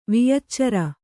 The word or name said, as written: ವಿಯಚ್ಚಾರಿ